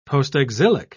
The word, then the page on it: postexilic: British English